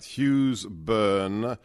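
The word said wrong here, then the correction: Hugh Keays-Byrne: He has a double-barreled name. And that is, I don't know if you did any research, Hugh Keyes Byrne.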